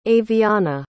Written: avyanna

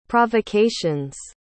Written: provocations

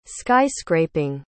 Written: sky-scraping

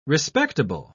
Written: respectable

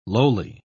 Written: lowly

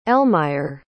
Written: Elmire

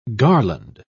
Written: garland

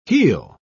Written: heel

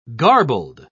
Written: garbled

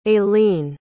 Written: ailene

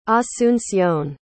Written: asuncion

